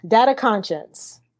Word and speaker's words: Data Conscience: The rundown of the book, it is Data Conscience mm-hmm. or Data Con Science, depending on how you want to pronounce it.